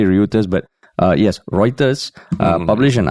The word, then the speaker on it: Reuters: Even though it's spelled a little confusingly, it's like Reuters. – I mean, it's as confusing as omelette, right? It's the pronunciation of omelette. – Hey, okay, omelette is different. This is literally R-E-U. Okay. It's Ryu. – Yeah. – It's Reuters. Reuters.